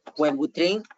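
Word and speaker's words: Wellbutrin: Nada más preguntan que si esa medicina se llama el Buelbutrin se escribe W-E-L-L-B grande U-T pasa R-I-N.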